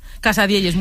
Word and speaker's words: casadielles: ¿Lo digo bien? – Casadielles, muy bien dicho.